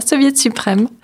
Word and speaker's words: Soviet Suprême: On passe donc maintenant la mi-novembre, on en arrive à un rendez-vous de chansons françaises à la souris verte, avec soviette euh, Soviet Suprême si je ne me trompe pas dans la prononciation du titre. – C'est bien ça, Soviet Suprême qu'on accueille pour notre plus grand plaisir donc à la Souris Verte.